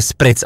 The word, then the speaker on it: spritz: PS a špric je bez háčku pro zbytek České republiky, takže spritz asi, že jo, jo. Italům to rve uši, objímám a zdravím, napsala Eli. Mám pocit, že spritz jsem ani snad neříkal, ne, doufám.